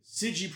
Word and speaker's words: CIDG: So the SIGI program is pronounced C-I-D-G, right? SIGI, right? But it's pronounced S-I-D-G-E-E. Like the phonetic way to say it.